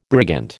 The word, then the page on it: brigand: American English